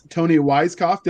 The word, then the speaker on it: Toni Weisskopf: Next, we have Miss Toni Weisskopf.